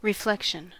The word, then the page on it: reflection: American English